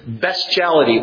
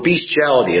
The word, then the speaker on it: bestiality: Fifthly, the seventh commandment prohibits bestiality or bestiality. It's pronounced either way.